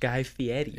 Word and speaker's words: Guy Fieri: It's pronounced Guy Fieri.